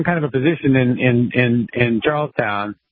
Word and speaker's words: Charlestown: Is it Charlestown or Charleston? – Charlestown. Yeah.